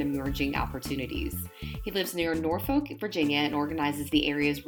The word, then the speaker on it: Norfolk: Also, we pronounce it as Norfolk, so it's no L in there. It's just Norfolk.